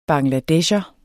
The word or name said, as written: bangladesher